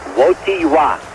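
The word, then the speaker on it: Wojtyła: Cardinal Carroll, to be perfectly honest, I can't even pronounce his name yet. Wojtyla? – It's Wojtyla. – Wojtyla. – Carol Wojtyla. – Wojtyla. Although you spell it Wojtyla.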